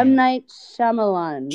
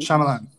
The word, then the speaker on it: M. Night Shyamalan: M. Night Shyamalan. – Shyamalan.